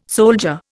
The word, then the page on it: soldier: Indian